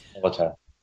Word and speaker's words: Hippolyta: Is it Hippolytia or something like that? – Hippolyta. – Yeah. Oh, what now? Say it again. Hippolyta? – Hippolyta.